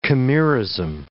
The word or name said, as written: chimerism